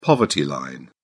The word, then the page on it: poverty line: British English